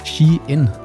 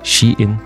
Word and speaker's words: Shein: Og det, der er en lytter, der er påpeget, det er altså udtales Shien. – She-In. – Ja, fordi det tidligere hed She Inside. – Mm. Og det var, det var for langt at bøvle, så derfor hedder det bare She-In.